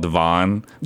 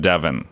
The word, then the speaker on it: Devon: And I think they say in England, they don't say Devon. There's a street in Chicago called Devon. – Devon. – But everybody else in the world pronounces it Devon.